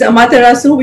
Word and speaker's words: Amaterasu: Yeah, it's Amaterasu, which is actually a Japanese word.